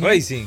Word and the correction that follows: Ryzen: ¿eh? Racing. No, Ryzen. Ah, yo pensaba que era Racing. Así que Ryzen se escribe con Z, Ryzen e Y.